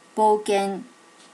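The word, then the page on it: bokken: native Japanese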